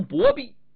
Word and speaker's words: 白璧: 那在说书上面，这个白璧就是咱们说的白璧，那也有人读成薄璧，因为白在过去呀，一上口就成了薄，白银薄银，白盔白甲白旗靠，薄银薄甲薄旗靠，都这么读，所以传统评书里头叫做白璧官。